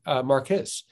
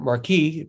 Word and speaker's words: Marquis: Another example is the philosopher Don Marquis, or Marquis, depending how you pronounce his name.